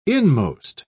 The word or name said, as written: inmost